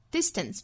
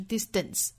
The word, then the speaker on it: distance: Distance 重音在第一个音节，后面这个 tense 的这个打手的 t 的发音不要做不送气的处理啊，不是 distance 而是 distance.